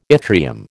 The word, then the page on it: yttrium: American English